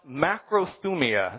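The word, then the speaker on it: macrothumia (μακροθυμία): Now, the word patience, as it shows up in this passage and as it shows up in the fruit of the Spirit, the word patience is pronounced macrothumia. It's a mouthful. Say that one with me.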